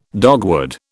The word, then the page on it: dogwood: British English